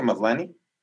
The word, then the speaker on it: Maslany: En exclusiva con Deadline, Tatiana Maslany, ¿se pronuncia Maslany? – Sí.